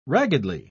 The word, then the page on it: raggedly: British English